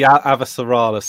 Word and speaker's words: Avasarala: Okay, so I think I will not like this for the same reason that you kind of like it, because Avasarala... Am I saying that right? – Yes. Yeah, you're good.